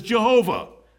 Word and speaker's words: Jehovah: which when pointed up with European vowels is pronounced as Jehovah.